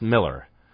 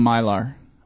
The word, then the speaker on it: Millar: His name is spelled... Millar, but it's pronounced Miller.